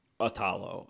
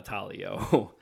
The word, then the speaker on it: Italo: Just, it's spelled I-T-A-L-O. – Italo. Okay. Italo, not Italio.